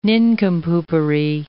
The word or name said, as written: nincompoopery